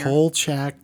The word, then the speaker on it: Kolchak: And how do you pronounce this last thing here? – Kolchak the Night Stalker.